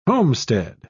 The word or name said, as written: homestead